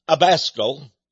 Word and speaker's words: Abascal: Father Abascal, sort of rhymes with rascal, doesn't it?